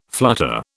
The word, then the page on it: flutter: British English